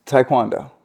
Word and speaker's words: taekwondo: Taekwondo. That's how you say it.